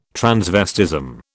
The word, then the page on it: transvestism: British English